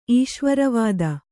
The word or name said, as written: ಈಶ್ವರವಾದ